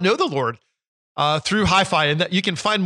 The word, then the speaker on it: HyFi: It's called Hi-Fi. It's spelled H-Y-F-I.